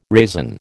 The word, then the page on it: raisin: American English